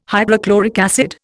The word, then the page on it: hydrochloric acid: Indian